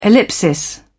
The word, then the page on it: ellipsis: British English